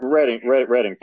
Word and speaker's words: Reading: Reading, by the way, would be... – Right. – the correct pronunciation, R-E-A-B-I-N-G. Continue. – Reading, thank you.